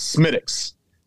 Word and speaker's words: Smithwick's: When I was in Ireland and I ordered a Smithwick's, they quickly corrected me. It's Smittick's. There's no W. There's no even in Ireland and Scotland, don't pronounce the W's.